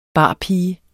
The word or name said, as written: barpige